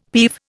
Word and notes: peeve: Indian

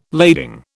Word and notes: lading: British English

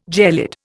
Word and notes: gelid: Indian

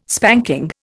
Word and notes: spanking: Indian

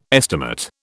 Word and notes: estimate: British English

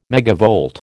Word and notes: megavolt: American English

